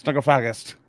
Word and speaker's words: Necrophagist: And Muhammad was like, "It's necrophagist."